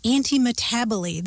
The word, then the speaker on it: antimetabole: And there are a couple of rhetorical terms that we can talk about, one of which is antimetaboly. This is an example of antimetaboly, which isn't one of your long-lost relatives. It's spelled A-N-T-I-M-E-T-A-B-O-L-E, anti-metaboly. And that comes from Greek words that mean something like opposite turning about or opposite change.